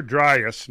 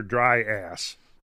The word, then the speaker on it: Dryas: So the Younger, like I say, it's Younger Dryas, not Younger Dry Ass.